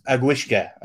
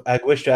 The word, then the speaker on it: Aughiska: And from the Bogs of Agwishka, I can't recommend that enough. – Which one? – From the Bogs of Agwishka. I'll have to spell it as like A-U-G-H-I-S-K-A.